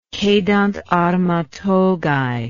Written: cedant arma togae

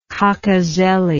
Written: cocozelle

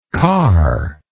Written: carless

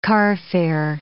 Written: carfare

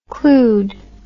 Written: clued

